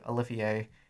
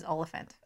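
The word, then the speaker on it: Olyphant: We have a running disagreement because I have grown up my entire life calling him Timothy Olivier. – And I pronounce it correctly as Oliphant.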